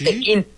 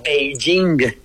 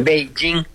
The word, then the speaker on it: Beijing: Ajá, así, sí, Pekín, sí. P-E-K-I-N. Ajá. Cuando la capital de China se pronuncia Beijing. – Sí. – Beijing.